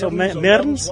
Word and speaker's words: Mearns: Mearns. Mearns rhymes with Burns.